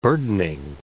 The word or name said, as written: burdening